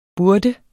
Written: burde

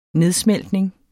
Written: nedsmeltning

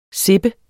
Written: sippe

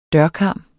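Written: dørkarm

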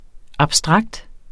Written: abstrakt

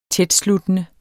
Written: tætsluttende

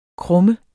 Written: krumme

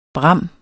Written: bram

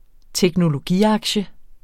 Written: teknologiaktie

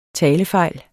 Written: talefejl

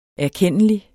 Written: erkendelig

